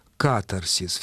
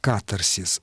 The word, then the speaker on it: катарсис: Я сейчас пытаюсь проверить ударение в греческом слове. Катарсис. Все-таки нам рекомендуют катарсис. Хотя понятно, что катарсис распространенный акцентный вариант, поскольку ударение стремится к середине слова, в многосложных словах.